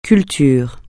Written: culture

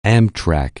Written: Amtrak®

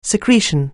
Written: secretion

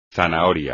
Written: zanahoria

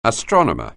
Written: astronomer

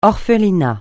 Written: orphelinat

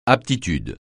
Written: aptitude

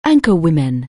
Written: anchorwoman

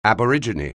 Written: Aborigine

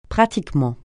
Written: pratiquement

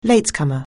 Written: latecomer

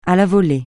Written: à la volée

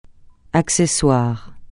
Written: accessoire

